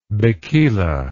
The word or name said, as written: bikila